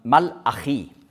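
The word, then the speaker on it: Malachi (מלאכי): Ja, ich habe ja gesagt, also Malachi, so sagt man das eben auf Deutsch ausgesprochen, aber Hebräisch, da sagt man Malachi.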